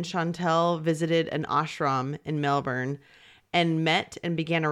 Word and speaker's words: Melbourne: I'm going to say Melbourne. I can't. – No, that's how you say it.